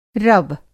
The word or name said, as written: रव